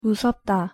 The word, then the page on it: 무섭다: Korean